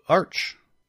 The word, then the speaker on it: arch: So arch. I like that. I think, yeah, I was definitely an archangel guy growing up, but I don't know why. There's no other word that's A-R-C-H that's pronounced arc.